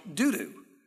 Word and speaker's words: Dodo: Actually, Dodo may actually be pronounced, it sounds even funnier if I pronounce it the way they do now in Israel, doo-doo.